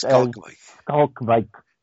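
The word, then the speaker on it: Schalkwyk: It's S-C-H-A-L-K W-Y-K. It's pronounced Skalkwyk.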